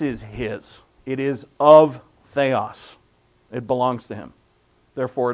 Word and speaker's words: theios: I know it's true, but This is theos, T-H-E-I-O-S, theos, really it's pronounced, and it means divine or of the one true God.